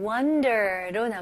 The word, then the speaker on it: wonder: 다음은 wonder로 넘어갈게요. 네, wonder 발음 연습해볼게요. 여기서는 O이지만 U 발음으로 생각해보세요. Okay? Wonder. Wonder. Wonder는 아니에요. Wonder. Wonder. Very good.